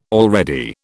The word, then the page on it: already: British English